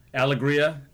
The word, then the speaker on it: Alegria: on Alegria Street. Is that how I should pronounce it? – Yes. Alegria. – Alegria. – Alegria, probably. – A-L-E-G-R-I-A Avenue. It's between Baldwin Avenue and Mountain Trail.